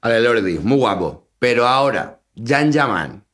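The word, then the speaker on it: Can Yaman: Se escribe Yan Yaman, se pronuncia Yan Yaman.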